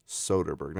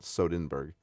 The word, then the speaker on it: Soderbergh: How do you say his last name? Is it Soderbergh? – Yeah. – Soderbergh, not Sodenberg.